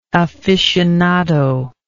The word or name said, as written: afficionado